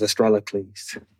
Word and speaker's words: Astrolicles: Please welcome a meteorite named Estroc- uh, Astrolicles. Is that, am I pronouncing it right? – Yes, it is. Uh, is Astrolicles. – That is my name.